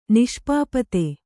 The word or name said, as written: ನಿಷ್ಪಾಪತೆ